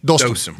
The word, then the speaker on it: Dostum: So so Dustin am I saying his name right? – Dostum. Dostum. Dostum.